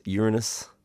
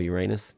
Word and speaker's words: Uranus: towards Uranus or Uranus, depending how you say it.